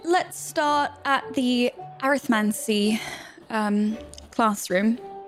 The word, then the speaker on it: Arithmancy: So for your period one, you have Care of Magical Creatures or Erythromancy. How do you say that word? Arithmancy? I got it.